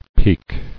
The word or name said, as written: Peek